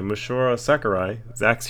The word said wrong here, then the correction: Masahiro: Um, Masahiro.